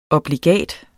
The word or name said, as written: obligat